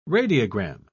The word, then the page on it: radiogram: British English